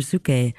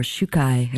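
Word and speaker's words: Czukay: And throughout the course of the album, you will hear Holger Zucke accompanying, or Shukai, as we've now learned to pronounce it, accompanying David Sovian, who plays the vocals, his vocal cords, the guitar, treated piano, synthesizer, percussion, and tapes.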